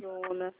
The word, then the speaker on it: Orizona: Orizona se escreve Orizona? – É Ourizona. – Orizona. Certo. Só mais um instante. Ourizona, é isso? – Isso.